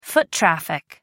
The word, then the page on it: foot traffic: American English